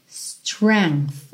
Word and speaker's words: strength: Atenção aí, que essa palavra tem uma escrita um tanto estranha, né? S-T-R-E-N-G TH, mas você pronuncia strength. Então começa no som do S. S, -s strength. Strength. Uhum. E com arzinho agora, esse TH, ok? No final.